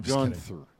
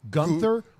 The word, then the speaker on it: Gunther: Then I heard dispute of how they're even pronouncing the name. Gunther? Gunther, yeah. – Like the H being silent, in order to avoid the comparison.